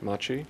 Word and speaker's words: Machi: Her 2016 book, Thunder Shaman, represents an extraordinary collaboration with the Mapuche shaman, or Machi, am I saying that right, Machi? – Yes.